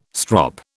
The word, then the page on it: strop: British English